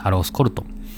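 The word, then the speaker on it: Aloxe-Corton: まあ、この中で、まあ、いろいろちょっと発音の違いで、例えばアロースコルトンとか、アクロスコルトンって読ませたりするところもあるんですけども、そこはまあ、相変わらず気にしなくていいです。アロースコルトン。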